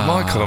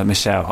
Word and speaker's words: Michel: Is it Michael or Michelle? – It's Michelle. I know it's Michelle.